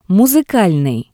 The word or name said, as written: Musikalnii